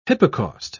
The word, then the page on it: hypocaust: British English